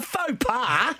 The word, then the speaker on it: faux pas: In fact, I made a forks pate yesterday. – Oh, can you say it properly, please? Please. – A faux pas. – Thank you. – F-A-U-X.